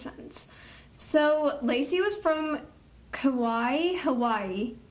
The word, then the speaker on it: Kauai: Kauai. – Okay. – You said it right, I think. – Kauai.